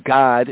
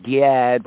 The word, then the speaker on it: Gad: Now, as we just touched on briefly with the Reubenites and the Gadites, or the B'nai God, you know, we say as God, it's not like God, because that's how it's said in the Hebrew, God. The English pronunciation is Gad, but really, that's a weird vowel right there, but it's God, right?